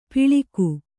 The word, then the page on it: ಪಿಳಿಕು: piḷiku